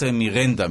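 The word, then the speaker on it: Miranda: קודם כל דיברנו מעט על הצהרת מירנדה, מסתבר שכך מבטאים את השם הזה, אותה הצהרה ששוטרים אמריקאים בדרך כלל אומרים כש...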